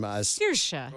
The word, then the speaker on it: Saoirse: And how do you pronounce her name? – Saoirse.